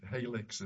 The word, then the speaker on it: Halex: Is it Halex? Am I pronouncing that correctly? – Yes.